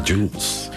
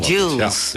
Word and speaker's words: Jools: Zeg ik dat goed? – Jools. – Okay. – Jules. Ja.